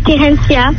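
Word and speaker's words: Therencya: Je m'appelle Terencia.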